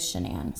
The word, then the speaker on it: Shanann: because it looks like Shannon, but it is Shanann. So Chris and his family and her family and like some of the prosecutors referred to her as Shanann. – Shanann.